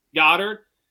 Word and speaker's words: Goedert: How do you say it? – Goddard.